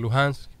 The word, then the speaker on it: Lugansk: de Lugansk, que la H rusa, la H cirílica, se pronuncia como la G, y la República de Donetsk, también popular.